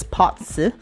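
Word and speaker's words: Bazi: So this time, as you know, I've been, I could consider myself as an enthusiast in terms of the potsu reading or the destiny reading. That's B-A-Z-I, but it's pronounced as potsu.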